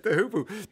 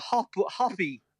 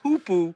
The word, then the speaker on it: hoopoe: But also, we often get people ringing us saying, I've had a hoppy. – Oh, yes, hoppy, yes. Because it's spelled H-O-O-P-O-E. That's the spelling of it. – But it's hoopoe. That is the pronunciation.